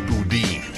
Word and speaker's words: Boudin: It's pronounced Boudin.